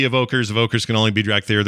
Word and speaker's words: Drakthyr: In particular, players are going to be able to start a new race called the Drakthyr. Am I saying that right? I've been saying that way all day. Is that right? – Yep. – Okay, easy enough.